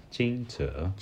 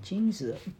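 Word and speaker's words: Jingzi (惊蛰): Jing. Zhe. – The third tone. – Jingzi. – Yes, Jingzi. – Jingzi.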